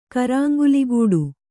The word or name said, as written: ಕರಾಂಗುಲಿಗೂಡು